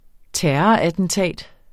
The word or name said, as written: terrorattentat